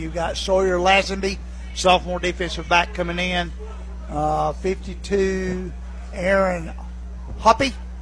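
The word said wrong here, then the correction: Haupt: or Hopt, H-O-P-P-T, H-A-U-P-T, but it's pronounced Hopt, bingo.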